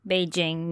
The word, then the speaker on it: Beijing: Beijing, meaning northern capital, is how it's pronounced in Mandarin, but Peking was the way it was pronounced and spelled by foreigners back in the olden days.